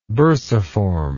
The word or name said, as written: bursiform